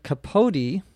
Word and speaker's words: capote: As you know in English, the E at the end of the word is usually what we would say silent. That is, you do not pronounce it. But in capote, you do.